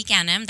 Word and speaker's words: Ganem: Should be Eddie Ganem, and I'll spell it because it is a weird name. So it's Eddie Ganem, that's E-D-Y. G-A-N-E-M.